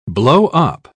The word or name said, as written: blowup